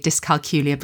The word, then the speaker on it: dyscalculia: So if whatever you would like to call it or however you've heard it, I'm going to be calling it dyscalculia because I've heard some people on YouTube calling it this. So I think that that's the correct pronunciation now.